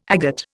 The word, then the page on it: agate: Indian